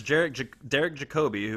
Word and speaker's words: Jacobi: And then I've heard his name pronounced both ways, but I always just call him Derek Jacobi. But people, I've heard it Jacobi. – Yeah. – But I'm pretty sure it's Derek Jacobi, who is one of my favorite actors on the planet.